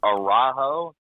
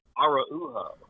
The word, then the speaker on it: Araujo: That was a challenging one, because the first time I thought, I was like, is it Araujo? And they were like, no, it's Araujo.